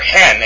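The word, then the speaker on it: hen (ἕν): Thus, when we pronounce the Greek form N, or hen actually, I'm sorry, meaning one, it's pronounced hen, because over the epsilon, there's a rough breathing mark. So, epsilon nu with a comma that is rough is pronounced hen.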